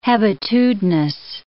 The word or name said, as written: hebetudinous